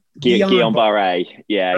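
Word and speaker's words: Guillain-Barré: And what they discovered was this increased the vaccine that they used increased exposure to, I always call this Guillain-Barre, and that's not the pronounce, it's French, Guillain-Barre. – Guillain-Barre, yeah.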